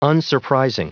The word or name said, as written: unsurprising